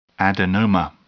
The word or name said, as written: adenoma